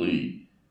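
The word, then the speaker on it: Li: Lee, L-I, is how it's spelled, Lee, M-D.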